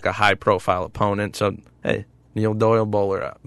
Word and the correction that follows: Boiler Up: Boiler up. I didn't even say it right. – Nope, you did not.